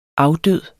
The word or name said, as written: afdød